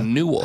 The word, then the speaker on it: newel: a newel. – How do you spell that? – N-E-W-E-L. – Okay, that's how I thought you would spell yeah. it. All right. Rhymes with jewel.